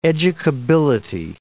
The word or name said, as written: educability